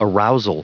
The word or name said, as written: arousal